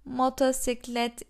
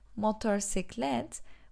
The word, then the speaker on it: motosiklet: Motosiklet ile. Motosiklet ile. In this one, when we say motorcyclet, we don't actually pronounce the R in the end of motor. So we say like motosiklet, motosiklet, like this.